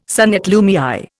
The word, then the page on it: son et lumière: Indian